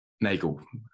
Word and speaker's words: Nagel: Nagel.